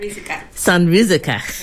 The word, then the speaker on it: Sanrizuka: Summer in Sanrizuka. I might not have pronounced that. – Sanrizuka.